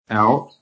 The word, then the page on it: OUT: American English